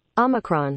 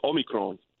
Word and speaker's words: Omicron: It's like basically saying the word homie, taking the H out. Omi. And then, did you ever hear the guy? – This is not what the computer says. – Omicron. – Yeah, but he's Greek. – Okay. – Yeah, so the Greek god Kronos, so you take those two words, Omi and Kron, so it's Omicron.